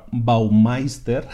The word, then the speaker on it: Baumeister: La autoridad número uno del planeta en temas de fuerza de voluntad es un señor que se llama Roy Baumeister, ahora lo digo bien, y que escribió un libro que se llama así, Willpower, Fuerza de Voluntad.